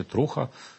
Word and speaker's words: Труха: Щодо свободи слова, я тут не буду вже казати про кейс Еспресо, але скажу про інший кейс, це так званий телеграм-канал Труха, чи Труха, як вони наполягають з наголосом.